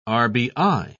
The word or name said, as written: rbi